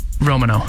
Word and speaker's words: Romano: How do you say it? – It's Romano. – It's what? – Romano. – Romano. – No. – Like, no, Mark, you were wrong.